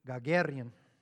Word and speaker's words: Gagarin: And then one thing that really threw us for a loop was when Gagarin, got to roll your R in there, made this comment, I'm up here and I don't see God anywhere.